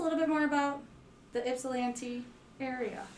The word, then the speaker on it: Ypsilanti: So the Y is silent. – The Y is silent. So yes, I have been saying it wrong on purpose. It is Ypsilanti. – Or it's not silence, but it's an I instead of a Y.